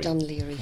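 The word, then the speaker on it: Dún Laoghaire: Dunleary, yes.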